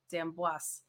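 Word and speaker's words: D'Amboise: I think you pronounced the S. I think I said it wrong. Dambois.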